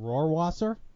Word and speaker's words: Rohrwasser: And they drafted one in the fifth round, Justin Rohrwasser. Am I pronouncing that correctly? – I believe so. – That was a pretty good job. That was a really good job. – I went phonetic.